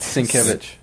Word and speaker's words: Sienkiewicz: I don't remember Jim Aparo like be, ever being inked by Bill Skowinski or Skywinski, if I said his name right. – But you Sienkiewicz. can tell Sienkiewicz. Is that how you say it? Sienkiewicz. Sienkiewicz. Sorry, sorry, Bill.